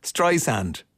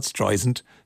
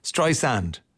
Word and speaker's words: Streisand: He says it's Streisand, not Streisand, Streisand.